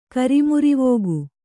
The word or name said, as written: ಕರಿಮುರಿವೋಗು